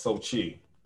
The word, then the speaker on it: Xochitl: It's pronounced Sochi. – Oh. Xochitl Gomez? – Yes. No, Sochi. That's it. – Oh, that's it? Just Sochi Gomez? – Yes.